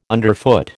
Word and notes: underfoot: American English

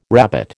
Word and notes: rabbet: American English

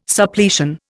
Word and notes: Suppletion: Indian

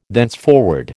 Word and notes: thenceforward: American English

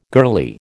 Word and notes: girlie: American English

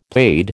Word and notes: paid: American English

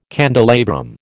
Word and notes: candelabrum: American English

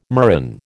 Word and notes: murrain: American English

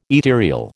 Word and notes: ethereal: American English

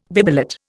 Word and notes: bibelot: Indian

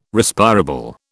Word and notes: Respirable: British English

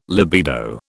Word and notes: libido: British English

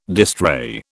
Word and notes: distrait: British English

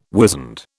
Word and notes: wizened: British English

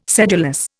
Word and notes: sedulous: Indian

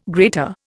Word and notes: grater: Indian

